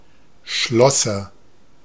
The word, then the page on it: Schlosser: /ˈʃlɔsɐ/